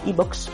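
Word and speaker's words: iVoox: Y luego, si estáis escuchando este eh, podcast en un programa de podcast, eh, tipo Podcast, Spotify, Evox, donde sea, Evox, perdón, que no lo he dicho bien,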